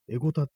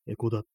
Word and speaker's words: 江古田: エゴタって読むところと、エコダって読む、エコダが正しいという読み方であるっていうね、なんかその両方ともあるっていうことらしいんですよ。